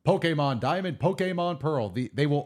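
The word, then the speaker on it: Pokémon: But the way they want it pronounced officially is Pokemon.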